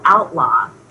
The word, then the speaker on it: ALTLAW: And so that led us to come up with this new category that we call outlaw, which is spelled A-L-T-L-A-W. So you have outlaw and then you have outlaw.